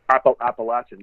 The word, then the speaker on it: Appalachian: Appalachian, you'd say. – Yeah. What would you say, Maryland or in Pennsylvania, Maryland and stuff? Like, how do you say that? – No, you're 100% correct.